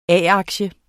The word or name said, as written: A-aktie